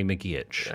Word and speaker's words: McGeoch: The correct pronunciation is apparently McGeech.